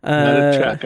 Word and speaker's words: Matichak: Matichak, I think is how it's pronounced.